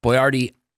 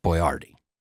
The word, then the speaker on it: Boyardee: So it's the accent is on boy. – I mean, what people say is Boyardee. – Yeah. – But it's, I think it's Boyardee.